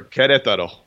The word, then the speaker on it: Querétaro: Querétaro.